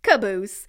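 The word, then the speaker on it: Qabus: And then there's Q-A-B-U-S, and I looked it up, and it's pronounced Caboose.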